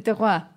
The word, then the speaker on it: terroir: terror. – Ah, el terror. – El terroir. Que se, que se escribe terror.